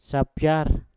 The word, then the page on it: sapyar: mpur